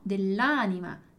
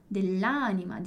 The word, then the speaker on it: dell'anima: Então, bem importante lembrar, e lembrar principalmente da pronúncia, né? Então, dell'anima, no, dell'anima. Dell'anima, dillo chiaro. Dell'anima, olha como flui muito mais, é muito mais sonoro, é mais harmônico.